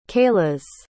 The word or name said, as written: kailas